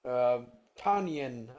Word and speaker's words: Tonyan: Tonyan, I think, is how you say his last name.